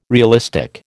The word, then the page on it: realistic: American English